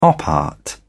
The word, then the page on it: op art: British English